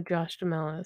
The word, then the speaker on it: Josh Duhamel: Okay, I know who Josh Duhamel is.